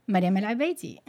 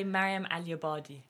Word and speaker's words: Mariam El Abadi: And I will be accompanied by the lovely and talented studio manager, Mariam El Abadi. Is that how you say it? – I say Mariam Al Abadi. – Mariam Al Abadi. – It's the Arabic versus the Farsi.